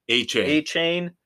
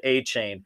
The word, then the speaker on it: Achane: Achane. Yep. – That's right. Achane. – Rhymes with A-Train, baby.